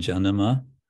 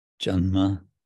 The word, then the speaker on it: janama: And Janama means birth with a little extra syllable, janma, and tara, of him, tara.